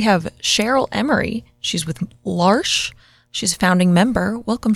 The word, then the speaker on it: L'Arche: L'Arche, first of all, the way you pronounce it, it rhymes with marsh.